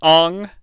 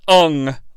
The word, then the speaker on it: Aang: I guess in Avatar, The Last Airbender, his name is Aang. Like, A-A-N-G is how you spell it. But everybody in this movie, or most people in this movie, seem to say Ung, like U-N-G.